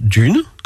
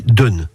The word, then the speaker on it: dune: Dans les parlers normands, on prononce dune, comme en français standard, depuis Trouville jusqu'à Port-en-Bessin. Au-delà, plus à l'ouest, sur les côtes du Bessin et dans le Cotentin, la voyelle s'est ouverte et l'on dit dune. À partir du pays Grand-Villet et jusqu'en Bretagne, on retrouve la prononciation standard dune.